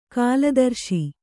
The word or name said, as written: ಕಲುದರಸಿ